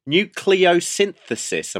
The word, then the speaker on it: nucleosynthesis: I think it's nucleosynthesis.